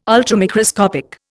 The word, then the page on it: Ultramicroscopic: Indian